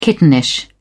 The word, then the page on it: kittenish: British English